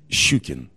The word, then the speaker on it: Shchukin: Shchukin.